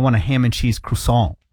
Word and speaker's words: croissant: Is it croissant? – Croissant. – Oh. Well, but that's not the proper pronunciation.